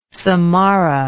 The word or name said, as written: samarra